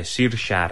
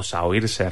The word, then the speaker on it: Saoirse: Ya veréis para qué, si no habéis visto esa película, que no sé qué estáis esperando para verla, pero bueno, aparece como Saoirse Ronan, ¿vale? O Saoirse Ronan, como se escribe.